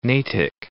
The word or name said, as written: Natick